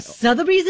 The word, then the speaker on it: Sotheby's: Yeah, so literally, like, Sotheby's is doing an auction of this chair that he bought. – Oh, Sotheby's is doing this?